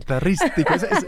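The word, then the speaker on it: Guitarístico: You are also a graduate from the studio, the Arte Guitarisco. – Yes. – Did I say that right? – Guitaristico.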